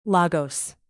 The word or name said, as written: lagos